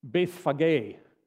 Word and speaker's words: Bethphage: Usually, you'll hear it pronounced Bethphage. For those of you who want the official pronunciation, or you want to know what it really is supposed to sound like, Bethphage is what it would be pronounced, something like that in biblical terms, Bethphage or Bethphage.